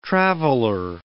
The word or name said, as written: traveller